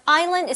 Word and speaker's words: island: Mm-hmm, and remember, island is spelled I-S-L-A-N-D. – True. – But the S, we do not pronounce it.